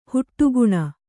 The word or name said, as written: ಹೊಡಕಣೆ